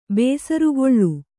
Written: ಬೇಸರುಗೊಳ್ಳು

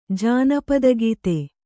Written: ಜಾನಪದಗೀತೆ

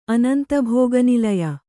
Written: ಅನಂತಭೋಗನಿಲಯ

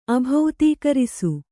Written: ಅಭೌತೀಕರಿಸು

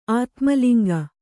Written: ಆತ್ಮಲಿಂಗ

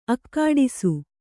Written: ಅಗಡಿಸು

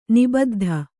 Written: ನಿಬುದ್ದಿ

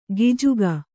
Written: ಗಿಜುಗ

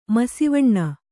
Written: ಮಸಿವಣ್ಣ

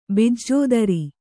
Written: ಬಿಜ್ಜೋದರ

